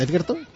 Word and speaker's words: Edgerton: Edgerton, como se pronuncia. – Eso lo habías dicho bien antes. – Edgerton, vale, Edgerton, bien.